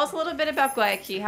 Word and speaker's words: Guayaquil: Guayaquil. Am I saying that right? – You said it right.